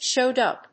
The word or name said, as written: showed up